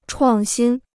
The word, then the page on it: 创新: chuàng xīn